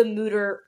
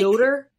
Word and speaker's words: Mütter: It says, quote, "The U in Mütter has an umlaut. In German, the U, with like the two little dots on top, is properly pronounced with an E-O sound. We are just as happy if you pronounce it with a long U sound like scooter."